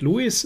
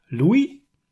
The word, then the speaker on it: Louis: Also der Vorname ist nicht Louis, sondern Louis tatsächlich. Ich denke, das ist wenig überraschend.